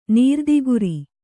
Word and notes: ನೀರ್ದಿಗುರಿ: nīrdiguri

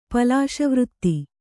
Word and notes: ಪಲಾಶವೃತ್ತಿ: Kannada

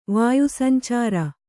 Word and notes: ವಾಯುಸಂಚಾರ: kannada

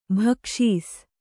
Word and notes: ಭಿಕ್ಷಿಸು: Kannada

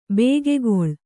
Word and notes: ಭಾಗಗೊಳ್ಳು: bhāgangoḷḷu

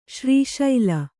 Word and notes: ಶ್ರೀಶೈಲ: śrī śaila

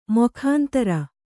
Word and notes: ಮೊಖಾಂತರ: mokhāntara

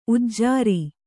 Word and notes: ಉಜರು: ujaru

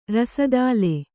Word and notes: ರಸದಾಲೆ: rasadāle; Kannada

